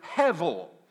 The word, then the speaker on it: hevel: When, when, when he says that, he uses the Hebrew word hevel. It is spelled H E V E L, hevel.